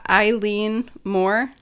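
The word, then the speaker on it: Eilean Mòr: It says that it's pronounced Eileen Moor.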